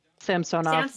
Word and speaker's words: Samsonov: Ilya Samonov, Samsonov, also former Capital goalie. – You are butchering the former Capital goalie's last name.